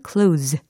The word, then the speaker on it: close: 그랬을 때는 끝소리가 s 이렇게 끝납니다. 근데 만약에, 어, close the door 이와 같이 동사로 쓴다면 close 이렇게 발음이 된다라는 거, 끝소리가 유성화 된다라는 거, 요 차이가 있습니다.